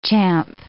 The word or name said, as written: champ